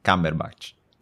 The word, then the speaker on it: Cumberbatch: Ecco, adesso dillo, dillo, Benedict dillo bene. Cumberbatch e per il potere del cane, ho detto bene? – Sì.